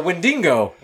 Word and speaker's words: Wendigo: The Wendigoon? Wendigo. – Oh, but God, I was going to say Wendigo. I was literally going to say Wendigo. But what did Joseph call it? The Wendigo.